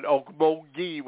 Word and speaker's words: Okmulgee: I'm going to introduce you to Renee Dove, the superintendent over at Okmulgee with that hard G.